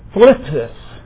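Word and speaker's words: thlipsis: Phlipsis is how you pronounce that. Phlipsis.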